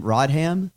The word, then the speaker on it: Rodham: Hillary Rodham Clinton. How do you say her middle name? Rodham? – Rodham.